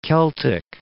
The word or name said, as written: Celtic Sea